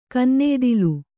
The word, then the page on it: ಕೆನ್ನೆಯ್ದಿಲೆ: Kannada